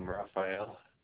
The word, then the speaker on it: Raphael: Sure, Raphael. – Can you spell that for me? – Sure, R-A-P-H-A-E-L.